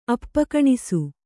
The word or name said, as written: ಅಪ್ಪಕಣಿಸು